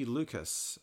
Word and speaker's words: Lukacs: And then Sophie Lucas, that last name is spelled L-U-K-A-C-S, who hails from Montreal, and she's got a European ancestry.